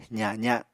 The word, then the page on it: zaza: apatani